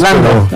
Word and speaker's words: Landau: Orlando, no sé cómo se pronuncia en inglés. – Landau. – Pero es como es un apellido alemán, confunde.